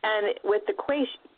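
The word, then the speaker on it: quaich: I think it's a quaish, which it's a Gaelic word, so it's probably spelled, you know, like S-O-M-E or something. – Right. – Ridiculous, but it's pronounced quaish. I'm pretty sure that's how it's pronounced.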